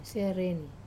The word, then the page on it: sereni: Wamesa